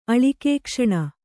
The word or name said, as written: ಅಳಿಕೇಕ್ಷಣ